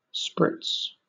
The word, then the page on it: sprits: British English